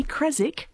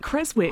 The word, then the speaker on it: Creswick: because it should be Creswick, not Creswick. That W should not be pronounced.